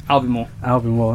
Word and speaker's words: Albemarle: What makes you think that Albemarle, how do you pronounce the name? – Albemarle.